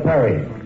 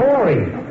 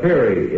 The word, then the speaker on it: Peary: This was the voice of Admiral Peary, who, It's Admiral Perry. – No, Peary. – That's so. And who is the guy in Lake Erie? – That's Peary. – You're right. It's Peary. It's spelled P-E-A-R-Y, right? – Just a minute.